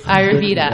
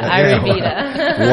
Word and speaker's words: Ayurveda: Ayurveda? – Ayurveda. Ayurveda. – Whatever. What is it? – So Ayurveda is a system of medicine that came from India. So with Ayurveda, they don't use acupuncture in Ayurveda, but it's mostly different herbal medicines and a system of working with diet.